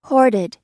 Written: hoarded

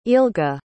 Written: ealga

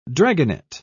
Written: dragonet

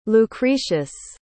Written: lucretius

